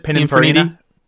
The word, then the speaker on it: Pininfarina: Pininfarina.